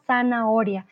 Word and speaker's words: zanahoria: Entonces, la zanahoria. Recuerden que aunque la H está ahí en la mitad, no la pronunciamos, ¿vale? No pronunciamos la H en este caso.